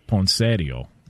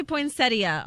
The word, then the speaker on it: poinsettia: Well, the in-laws would call it poinsettio. – Oh, gosh. – Okay. What up? You know what I'm saying? Can we get the proper pronunciation on this? – It does say poinsettia online.